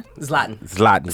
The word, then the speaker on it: Zlatan: Zlatan.